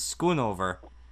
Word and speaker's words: Schoonover: Okay, well, I guess I can live with that since mine is Schoonover, and... I tell people all the time, it you say it like it's spelt.